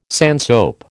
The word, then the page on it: sandsoap: American English